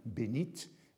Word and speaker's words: bénite: C'est un usage qui est formalisé au XIXe siècle, et il y a donc une variante qui conserve la prononciation au féminin, bénite, du T latin. Benedictus, Benoît, a un T, Bene, a un T, c'est ce T-là qu'on trouve dans bénite.